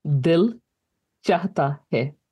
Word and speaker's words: Dil Chahta Hai: And how do you say the name of this piece again? – Dil Chahta Hai. I don't make it. – Dil Chahta Hai. – You got it.